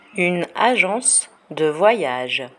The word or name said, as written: une agence de voyage